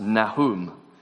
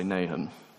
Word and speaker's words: Nahum: A friend of mine who was studying Hebrew at the time once told me, no, it's pronounced Nahum. And I said to him, you pronounce it like that all you like. I'm going to say Nahum.